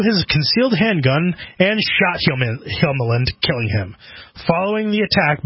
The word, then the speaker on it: Hjelmeland: After being refused entry into the strip club for belligerent behavior and racist comments earlier in the evening, Thomas Elliot Kjelmeland... Sorry, what I just it? Like that. I like that name because it's spelled H-J-E-L-M-E-L-A-N-D. Kjelmeland.